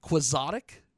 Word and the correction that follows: Quixotic: I call it Exotic Quixotic because of Don Quixote, but, you know.